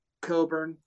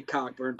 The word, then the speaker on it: Cockburn: And I think AO and Cockburn, however you want to say it. I say Cockburn. – I'm sorry, that's got a CK after the CO, and that's in our language pronounced a certain way, but whatever.